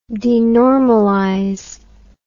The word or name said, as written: denormalization